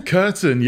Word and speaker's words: Kurten: Kurten, yeah. Like, people get confused with something you put over the window, but it's not spelled like that.